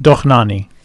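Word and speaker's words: Dohnányi: It's just Dohnanyi. – Dohnanyi. – I thought Dohnanyi. – So he doesn't... – I think it's first syllable.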